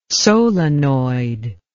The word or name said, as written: solenoid